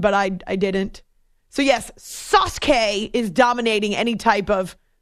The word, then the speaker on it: Sasuke: Sasuke. – Sasuke. So the U is silent.